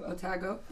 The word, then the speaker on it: Otago: She studied economics and music, so she has two very strong interests at Dalhousie University, University of Otago. Not sure I said that right. – Yeah, no, spot on.